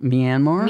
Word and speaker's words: Myanmar: Myanmar.